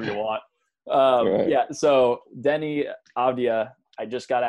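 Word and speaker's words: Deni: Yeah, it's Denny, which usually it's not a common name in Israel. Usually Israelis call it Danny, Danny is very common. But Denny, it's not so common at all.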